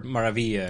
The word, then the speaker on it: Maravilla: I prefer Maravilla, because it sounds like Maravilloso.